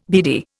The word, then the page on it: beady: Indian